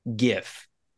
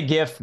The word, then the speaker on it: GIF: Now I just said it, so that's how G-I-F. I say it. I say GIF. Other people say that it's supposed to be GIF, like peanut butter. – No, I don't know. You say GIF because you're not on an FBI wanted list.